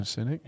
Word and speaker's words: Sinek: Like, there's a really good Simon Sinek thing. I think that's how you say it. – Yeah, Simon Sinek.